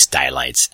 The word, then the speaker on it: Stylites: I've heard it pronounced Stylites, but it's really, I believe, Stylites. Stylites. Sounds like skylight.